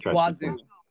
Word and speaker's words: oiseau: So yeah, the French word for water is something else, but I see so the French word for bird is O-I-S-E-A-U. So it's probably Wiso? – Wazoo. – Wiso? – It's pronounced Wazoo.